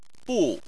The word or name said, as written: bu4